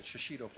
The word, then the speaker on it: shishito: Did I say it right? Yeah, that's it, shishito plant.